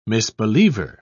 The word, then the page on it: misbeliever: British English